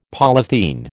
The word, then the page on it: polythene: American English